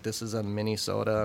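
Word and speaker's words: Mini-sota: We launched a lower potency called Minnesota, as in soda. – S-O-D-A? – S-O-D-A. – Ah! – And the way you spell it is M-I-N-I.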